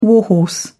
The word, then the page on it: warhorse: British English